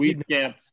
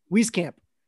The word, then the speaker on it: Wieskamp: Yeah, I would caution you on that one, at least, Jake, just because I know Joe Wieskamp. Am I saying that right? I feel like Wieskamp. – Wieskamp.